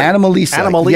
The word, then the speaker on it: Anomalisa: Anomalisa. Yeah, it's hard to pronounce. I won't give it away, but I found out why it's called that. It's a combination of anomaly and Lisa.